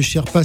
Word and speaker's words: Cherpa: Euh, je rappelle que Sherpa s'écrit C-H-E-R-P-A.